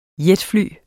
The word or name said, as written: jetfly